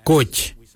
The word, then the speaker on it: Goodj: Karli is spelled G-I-L-Y-I, karli. For the traditional stone axe, we say koch, koch. Koch is spelled G-O-O-D-J, koch.